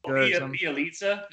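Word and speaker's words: Bjelica: And they got, how do you pronounce his name? It's like Bielitsa, Nemanja, Bielitsa. – Is that how you say it, Bielitsa?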